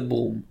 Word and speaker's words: Bruttenholm: כותבים את זה B-R-U-T-T-E-N-H-O-L-M ומבטאים את זה ברום.